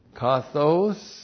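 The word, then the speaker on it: kathos (καθώς): And then we have kathos, comparative adverb. K-A-T-H, long O-S.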